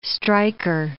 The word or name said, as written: striker